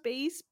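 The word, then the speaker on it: Béis: I think it's pronounced base bags.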